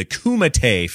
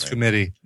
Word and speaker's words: Kumite: Kumite fight. That's how you pronounce it. – Oh, I thought it was committee.